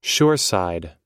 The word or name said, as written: shoreside